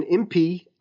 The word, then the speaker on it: Impey: The article quoting was written by Stephen Impey, I-M-P-E-Y is how you pronounce the gentleman's last name.